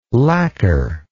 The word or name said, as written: lacker